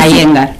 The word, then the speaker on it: Iyengar: A Iyengar. – A Iyengar, así aprendo. – Se escribe Iyengar. – Y yo digo así, pero cero, pero se pronuncia a Iyengar.